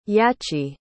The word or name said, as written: yachi